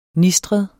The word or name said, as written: nistret